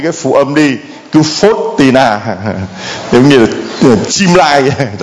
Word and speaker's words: Faustina: Để anh chị em tập phát âm cho nó đúng. Có nhiều người nói không biết đọc là thánh như thế nào. Thánh Faustina. Đó là thánh Faustina. Nhớ cao lên.